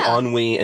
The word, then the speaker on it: ennui: And they had a sense of, you know, like, I can never pronounce this word, E-N-N-E-N-U-U-I, Anu, Enu, like when you basically have nothing going on and you're so bored that... – I don't even know what that is. No. – I'm like, that's a new word ever. – Wait, hold on. Spell this again. Hold on. Wait a second. Let me pull up the Google machine. – Enu. Hold on. It's E-N-N-U-I. – Ennui.